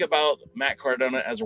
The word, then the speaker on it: Cardona: Matt Cardona.